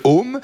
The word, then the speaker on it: heaume: Ça s'écrit H-E-A-U-M-E, le home.